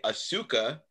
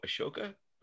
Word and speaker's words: Ahsoka: Spells her name A-S-U-K-A. Yes. That we were taught to not say Asuka. And now in my Star Wars world, there's Ahsoka spelled absolutely correctly, but that's my world right now. So Ahsoka, I'm saying it right. – Ahsoka, you got it.